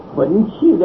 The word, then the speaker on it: 分际: 是在第二段的宣示大义，分六课：一教其因缘，二主张所舍，三义理的分际，这个分际两个字念都念成气声，分际，分际就是它的边际啊。